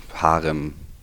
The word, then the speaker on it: Harem: Harem, sagt man Harem? Also es ist, oder wie spricht man es aus? Äh, Plakativ, äh, einfach simpel, Harem.